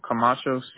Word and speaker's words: Camacho: Dennis, your first name, you spell that D-E-N-N-I-S, right? – Yes. – Okay, what's your last name? – Camacho, C-A-M-A-C-H-O.